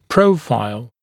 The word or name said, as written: profile